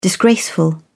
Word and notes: disgraceful: British English

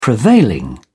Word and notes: prevailing: British English

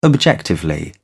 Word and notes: objectively: American English

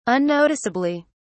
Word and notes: unnoticeably: British English

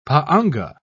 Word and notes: pa'anga: British English

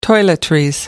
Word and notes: toiletries: American English

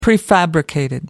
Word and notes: prefabricated: American English

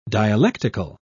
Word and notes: dialectical: British English

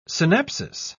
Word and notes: synapsis: British English

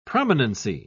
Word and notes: prominency: British English

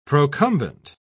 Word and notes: procumbent: British English

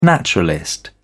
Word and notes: naturalist: British English

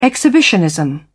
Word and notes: exhibitionism: British English

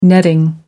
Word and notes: netting: American English

